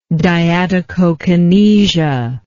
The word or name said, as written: diadochokinesia